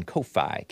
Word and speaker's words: Ko-fi: Base America First continues with another tip on Ko-Fi, K-O-F-I. Probably pronounced coffee, but I don't want to confuse it with the word coffee.